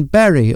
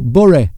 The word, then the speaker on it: Bury: You grew up in the northwest of England, in Bury, or Bury, as it's pronounced up there.